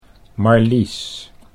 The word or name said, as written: Marlies